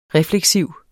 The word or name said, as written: REFLEKSIV